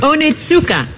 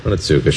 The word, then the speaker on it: Onitsuka: Onitsuka. – Onitsuka, sure.